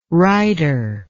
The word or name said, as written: righter